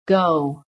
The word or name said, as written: GO